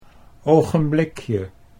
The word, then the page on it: ogenblikje: Dutch